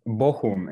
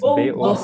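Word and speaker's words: Bochum: Luego, aparte, nos has recomendado Múnich para visitar en Alemania, pero ya más en tu localidad, en Goku, no sé si... – Bohum, es Bohum. Perdón, he dicho Goku como Dragon Ball. No, lo he pronunciado muy mal.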